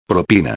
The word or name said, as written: propina